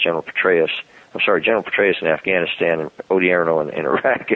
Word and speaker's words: Odierno: And that puts, you know, General Mattis in charge of Afghanistan, General Ray Odierno. I'm sorry, I've got to say his name right. Odierno.